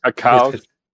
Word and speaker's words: Kakao: How do you pronounce that? K-A-K-A-O Games. – Kakao? – It is Kakao, yeah.